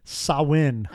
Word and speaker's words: Samhain: It's actually pronounced Samhain. – Oh, okay. – Completely wrong from what it looks like.